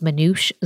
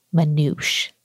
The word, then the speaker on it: Manoush: Hi, my name is Manoush Zomorodi. Manoush. I'll spell it. M-A-N-O-U-S-H. It's Persian.